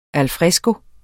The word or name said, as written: al